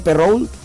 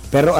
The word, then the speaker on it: Perrault: Perrault. Perrault, así es.